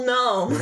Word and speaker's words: gnome: but otherwise is a normal with the silent G before the N. A normal gnome.